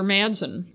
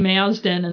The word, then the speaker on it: Madsen: I think I kept saying Mazden instead of Madsen. For some reason, it's hard to say Madsen.